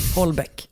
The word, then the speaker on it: Houellebecq: Vi läste Michel Houellebecq. Mm. Som han tydligen uttalas. Mm. Holbeck.